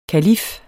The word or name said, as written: kalif